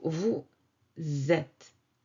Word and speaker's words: vous êtes: Usually, vous with that S, the S here is silent. And it is not pronounced, but when the following word starts with a vowel, like E accent circonflexe, both of them will link together through the pronunciation we call la liaison.